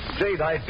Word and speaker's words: Jadeite: It's spelled J-A-D-E-I-T-E, Jadeite.